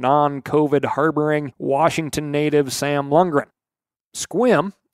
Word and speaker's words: Sequim: All you darned Washingtonians, from Spokane to Hump Tulips, I pronounce the town of Squim. Like, I'm sorry, I am wet. I just got done with a short squim, spelled S-E-Q-U-I-M.